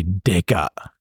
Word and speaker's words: deka: Or you would say deka. – Deka. – Which is like cop.